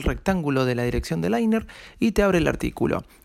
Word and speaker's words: Liner: La primera aplicación es Liner. Liner, ¿sí? Liner, que se escribe L-I-N-E-R, ¿sí?